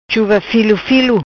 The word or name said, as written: Chuva Filo Filo